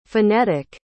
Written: phenetic

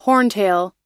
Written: horntail

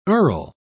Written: earl

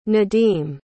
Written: nadim

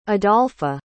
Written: adolpha

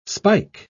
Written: spike

